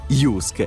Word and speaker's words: JYSK: Jusk è a Parma, in via Nicola Morigi, uscita dell'autostrada Parma. Condizioni su jusk.it. Si scrive JYSK.